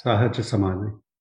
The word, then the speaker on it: Sahaj Samadhi: Did I pronounce the Sahaj Samadhi right? – Yes, Sahaj Samadhi.